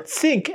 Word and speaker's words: ThinQ: My guess is when the when the phone actually comes out, you'll still see that ThinQ or think, as a lot of us have been mispronouncing for years before they corrected us.